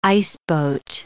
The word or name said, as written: iceboat